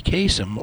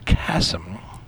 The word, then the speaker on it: Kasem: But just so you know, now you know, Casey Kasem, or Kasem, which is more likely the proper ethnic pronunciation, was one of us, an Arab American.